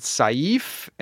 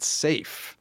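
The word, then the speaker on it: Saiph: Well, so back when I started Stargazers, the show, I always pronounce it Saif, and they corrected me and said, it's safe. You pronounce it safe.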